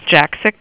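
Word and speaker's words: Jaksich: J-A-K-S, like Sam, I-C-H, pronounced Jacksick.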